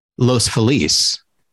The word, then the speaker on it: Los Feliz: I'm sure everyone who comes here probably says Los Feliz first, because that's the correct pronunciation.